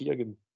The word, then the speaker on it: Keoghan: But uh, as you said yourself, Hunter Barrakia, I can never pronounce his second name, Barrakia, mm-hmm. Keoghan. that's it.